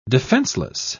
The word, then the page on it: defencelessly: British English